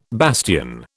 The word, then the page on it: bastion: British English